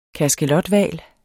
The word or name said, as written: kaskelothval